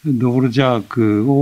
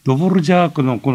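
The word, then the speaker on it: Dvořák: ドヴォルジャークのこの R の上にこうゴミがついてるようなルジュっていう発音がね、やりづらいんで、ロボルザークって、これはね、 NHK が悪いんだよ。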